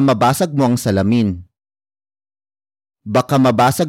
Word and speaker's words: mabasag: might the next word is mabasag mabasag Mabasag. Mabasag is spelled as M A B A S A G.